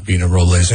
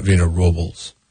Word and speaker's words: Vina Robles: They'd say, hey, it's actually Vina Robles. It's not Vina Robles. The poll question today is, how do you say it? Because last week, people were texting in on the 805 Beer text lines, and they're like, your guys are saying it wrong. It's not Robles. It's Robles. But these are people that are not.